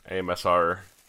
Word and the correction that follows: ASMR: You're not saying it right. – My asthma. – You're saying AMSR.